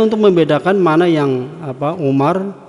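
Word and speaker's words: Umar (عمر): Kalau setelah tulisan apa itu mim apa ain mim dan ra itu ada wawu, ya, itu bacanya Amr atau Amrun. Tapi kalau enggak ada wawunya, ya, di belakangnya, itu dibaca Umar. Jadi wawu pada lafaz Amrun itu hanya sebagai tanda untuk membedakan mana yang dibaca Umar, mana yang dibaca Amr.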